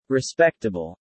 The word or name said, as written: respectable